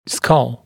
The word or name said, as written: skull